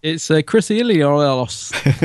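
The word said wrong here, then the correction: Achilleos: No, it's Chris Achilios? Achilios.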